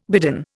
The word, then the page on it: bidden: Indian